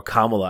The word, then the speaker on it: Kamala: And Kamala just didn't kind of answer, or Kamala, I guess is how she pronounces it.